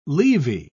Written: leavy

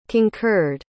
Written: concurred

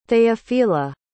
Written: theophila

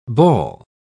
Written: bawl

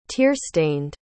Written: tearstained

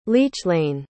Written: leachlainn

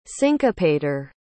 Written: syncopator

